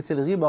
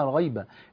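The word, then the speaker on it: الغِيبة: كتير من حبايبي مش قادرين يفرقوا بين الغيبة والنميمة، وبعضهم بينطق كلمة الغيبة غلط، بيقول ما هي الغيبة. لا، مفيش حاجة اسمها الغيبة، اسمها الغيبة والتانية اسمها النميمة.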